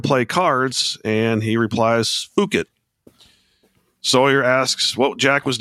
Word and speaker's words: Phuket: Spelled P-H, but it's pronounced Phuket. – Phuket, so no H, okay.